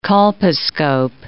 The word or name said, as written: colposcope